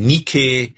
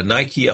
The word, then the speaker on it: Nike: Die alten Griechen und Römer hatten Zeus und Hera, dieses olympische Götter-Ehepaar, oder dann die Nike oder Nike ausgesprochen auf Englisch, die Siegesgöttin, die heute noch eine Sportartikelfirma benutzt.